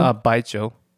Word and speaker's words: baijiu: Baijiu.